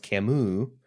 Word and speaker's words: Camus: which she helpfully says is pronounced Camus for me, called The Myth of Sisyphus, episode reference number 1, in which he introduced a philosophy of the absurd very briefly.